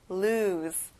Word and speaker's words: lose: 끝에 S 발음을 Z 발음이랑 비슷하게 해보는 거예요. lose.